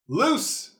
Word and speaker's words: loose: Loose rhymes with. – Moose. – Moose. Thank you.